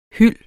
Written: hyld

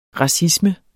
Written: racisme

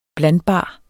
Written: blandbar

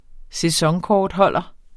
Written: sæsonkortholder

